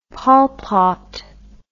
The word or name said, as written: Pol Pot